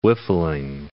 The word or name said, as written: whiffling